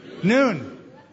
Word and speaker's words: Nun: Verse 8 Now Joshua, the son of Nun, the servant of the Lord. Actually, I said that wrong, didn't I? He's the son of Noon.